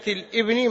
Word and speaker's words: الابن: قال: حدثنا هذه الهمزة يا عبد الله هي همزة وصل لا تلفظ، يعني لا يصح أن تقول باب ميراث ابنة الابن مع الابنة، هذا خطأ، لأن الهمزة هذه همزة وصل، فتقول باب ميراث ابنة الابن مع الابنة، هذه الهمزة تلفظ إذا بدأنا بها، تلفظ إذا بدأنا بها، لكن إذا وصلناها بما قبلها لا تلفظ.